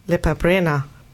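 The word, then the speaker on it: Lepa Brena: Nämlich Lepabrena.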